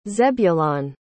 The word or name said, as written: zebulon